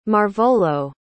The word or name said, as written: marvolo